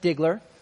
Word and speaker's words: Stigler: something a little bit about the congregation which the Lord has blessed me and my family is, it's pronounced Stigler, Oklahoma, very metropolis city, about 3,000 people.